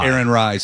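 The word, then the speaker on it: Aaron Rai: Aaron Rye.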